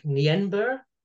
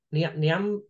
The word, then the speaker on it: Nienaber: So the other nominees were Ian Foster, Jacques Nienaber, Nianaba. – Yeah, it's Nina, but I can't admit, I was laughing at your pronunciation and absolutely bottled it myself.